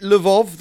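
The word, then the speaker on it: Lwów: is it Lvov that's spelled L-W-O-W? Yep. Yes, it's one of those L's with a line in it, who had family caught up in the genocide sanctioned by Frank and Otto von Wachter.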